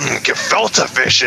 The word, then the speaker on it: gefilte fish: Yeah, should throw in some gefilte fish in there.